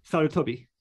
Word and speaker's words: Sarutobi: Sarutobi. – Sarutobi. Okay. – It's like it's not so much a toe, it's a top. Tobi.